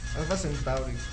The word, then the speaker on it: Alfa Centauri: ¿Qué significa Alfa Centauri? Alfa Centauri. Sí lo pronuncié bien, ¿no? – Sí.